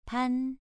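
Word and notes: pan: tone 2

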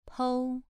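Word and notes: pou: tone 2

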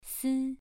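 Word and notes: si: tone 2

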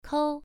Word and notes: kou: 1

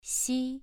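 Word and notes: xi: tone 2

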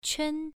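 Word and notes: quan: tone 1

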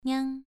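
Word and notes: niang: tone 2

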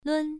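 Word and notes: lun: tone 1

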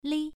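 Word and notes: li: tone 1

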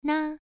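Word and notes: na: tone 1

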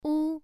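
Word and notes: wu: tone 1

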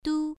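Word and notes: du: tone 1

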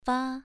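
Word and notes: fa: tone 2

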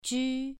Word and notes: ju: 2

